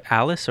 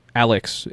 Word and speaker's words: Alyx: Half-Life Alice or Alyx? – Alyx. It's just, it's not, it's Alyx and it's spelled weird on top of that too. Okay. It's A-L-Y-X.